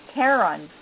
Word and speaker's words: Charon: But I think we're going to find a whole slew of moons because we think that Charon formed the same way, Charon or Charon, astronomers, you know, pronounce it different ways, formed the same way that our Earth's moon did from an impact.